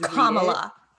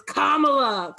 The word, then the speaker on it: Kamala: looking at a picture of Kamala, like Kamala Harris, or is it Kamala Harris? Am I stupid? – Kamala. – I don't watch the news. – It's just Kamala. – It's Kamala.